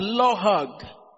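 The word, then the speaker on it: lohag (לעג): It's pronounced lohag. L-A apostrophe A-G.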